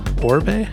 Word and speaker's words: Orbe: Before that, Function remix of Gregor Tresher, Antigone on Token, Blawan, Orbe? How do you pronounce that one? Any idea? Orb, but it's not the orb, it's O-R-B-E.